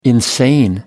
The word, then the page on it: insane: American English